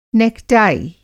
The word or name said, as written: नेकटाई